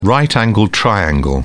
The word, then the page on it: right-angled triangle: British English